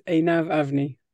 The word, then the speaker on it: Einav Avni: You know, it's a complicated one, but the way I pronounce it, it is Einav Avni.